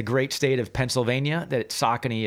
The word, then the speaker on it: Saucony: It is Saucony.